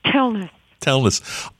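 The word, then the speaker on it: Telnaes: Is it Telnace? – Telnace.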